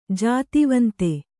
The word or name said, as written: ಜಾತಿವಂತ